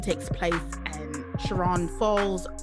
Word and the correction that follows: Chagrin Falls: Ohio. I think it's how you pronounce it. Chiron Falls. Chagrin Falls, Ohio, which is outside of Cleveland, which now makes sense now that I know that Abby's from Cleveland.